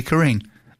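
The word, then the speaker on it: Corin: Corinne. – But there is an emphasis sometimes on the I, isn't there, Corinne? – There is, yes.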